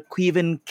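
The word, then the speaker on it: Caoimhín: With Alisson likely to be unavailable, backup goalkeeper Quiven Kelleher will start. If you've ever wondered how to pronounce the name, that was it.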